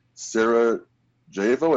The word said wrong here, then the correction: Sarajevo: It's Sarajevo.